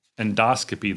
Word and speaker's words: endoscopy: Endoscopy, if you're curious, is the correct way to say it.